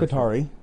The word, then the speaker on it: Qatari: Qatari.